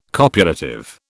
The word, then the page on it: copulative: British English